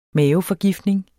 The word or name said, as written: maveforgiftning